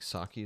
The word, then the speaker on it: Psaki: You know who Jen, I think Psaki is how you say it? P-S-A-K-I? – No.